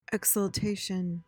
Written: exultation

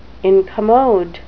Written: incommode

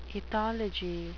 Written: ethology

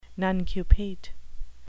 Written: nuncupate

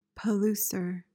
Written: palouser

